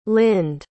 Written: lynd

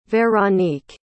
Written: veronique

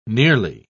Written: nearly

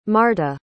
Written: marta

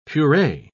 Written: puree

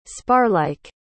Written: sparlike

